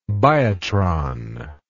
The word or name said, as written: biotron